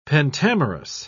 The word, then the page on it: pentamerous: British English